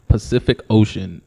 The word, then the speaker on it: Pacific Ocean: Every C in the word Pacific Ocean is pronounced differently.